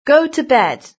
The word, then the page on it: go to bed: British English